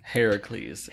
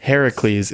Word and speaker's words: Heracles: Hercules is actually the Roman pronunciation of the name. – Heracles. – Heracles is the Greek.